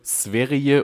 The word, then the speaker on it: Sverige: Es wird Sverie und Lüleo. Lyleo ausgesprochen. Okay.